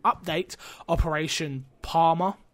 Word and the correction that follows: Parabellum: I think I said that right. – Parabellum. Um, parabellum. Yeah, Par- I was getting to the Bellum part. I knew that part, but par, Palum Parum Para.